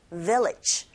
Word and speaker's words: village: They live 짧게 발음하시고 they live in the neighboring village. Village 하실 때 여러분 강세가 뒷부분에 없잖아요. 앞부분에 있잖아요.